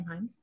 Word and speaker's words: Haim: I said Elena Haim, which has a nice internal rhyme, but it's not the way that her name is pronounced. Shout out to my friend Adam, who texted me to be like, hey, you said this wrong. And also, here's a playlist called Heim Time, which is how I remember how to say the last name Heim.